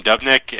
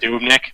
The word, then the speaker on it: Dubnyk: I just want to point out you called him Dubnyk, and I always mispronounce names, so I want to make fun of somebody else for mispronouncing a name. – Shit, what is it? – Dubnyk.